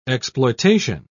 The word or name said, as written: exploitation